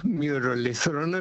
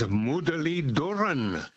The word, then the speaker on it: Muralitharan: Now, it is not Muralitharan at all. It is Mudaliduran. – Yes, the TH is a D, which is a dental T with, I think, your tongue touching your teeth, Mudaliduran.